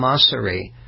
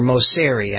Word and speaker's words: Mosseri: And the CEO, Adam Mosseri, or Mosseri, I think he pronounces it actually, of Instagram, was testifying before Congress about, among many other things, the impact of his platform on children.